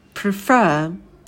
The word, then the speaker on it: prefer: Например, prefer, да, ударение на второй слог, мы будем удваивать букву «а», будет preferred, предпочитать.